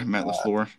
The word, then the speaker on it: LaFleur: I mean, he mentioned that his offense is being run by so many more coaches now, with McVay at the Rams, LaFleur, I think is that how you say his name? – Yeah, Matt LaFleur, yeah.